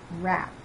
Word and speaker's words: wrap: W is silent before R at the beginning of a word. Write. They write a letter every week. Wrap.